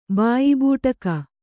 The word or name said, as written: ಬಾಯಿಬೂಟಕ